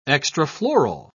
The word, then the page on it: extrafloral: British English